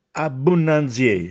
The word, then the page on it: Abbunnanziè: Castellanetano